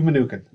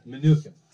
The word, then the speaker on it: Mnuchin: Steve Mnuchin. – Mnuchin.